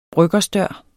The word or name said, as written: bryggersdør